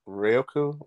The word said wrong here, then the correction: Raikou: Raikou? – Raikou. – Yeah.